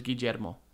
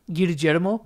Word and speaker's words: Guillermo: I got to pick a name in my Spanish class in Spanish, and it was Guillermo. – Why'd you say it like that? – That's how it's said. – Guillermo? – Guillermo.